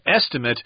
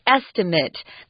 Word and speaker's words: estimate: The noun is estimate, kind of that is sound at the end, but the verb is the A sound, long A, estimate.